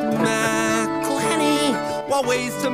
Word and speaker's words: McElhenney: It's McElhenney, McElhenney. If there was doubt, now there won't be any. It's McElhenney.